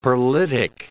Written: pearlitic

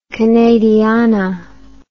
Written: canadiana

